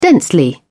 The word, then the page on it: densely: British English